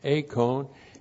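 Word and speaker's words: eikon: An acone looks like this. E-I-K, long O-N, acone. An acone means image or likeness.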